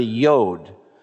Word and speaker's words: yod: It looks like that, just like a little apostrophe, and it's pronounced y, like a Y sound. So that's what a yod is.